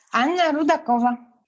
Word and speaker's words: Anna Rudakova: Anna Rudakova. – And that's spelled A-N-N-A-R-U-D-A-K-O-V-A.